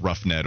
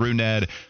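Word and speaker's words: Rougned: I did put a rejoin of a lot of my mishaps on the air where I mispronounced Roughnet Odor. Instead, yes, of course, it's Runed.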